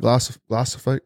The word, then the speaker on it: glyphosate: Because I was listening yesterday, they were talking about spraying all that glyphosate. Am I saying that right? – Glyphosate.